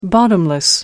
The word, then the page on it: bottomless: American English